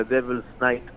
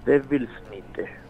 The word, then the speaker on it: Devils Nite: Y la banda, para que esté escuchando, se pronuncia Devil's Night y se escribe Devils, como diablos en inglés, Devils Nite, N-I-T-E, todo junto, Devils Nite.